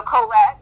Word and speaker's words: Kollet: And it's K-O-L-L-E-T. – K-O-L-L-E-T, Colette. And not Colette, because I used to pronounce your name wrong all the time.